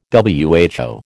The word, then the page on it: WHO: American English